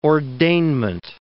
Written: ordainment

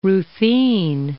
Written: Ruthene

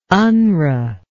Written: unrra

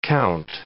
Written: count

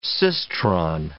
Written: cistron

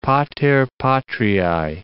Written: pater patriae

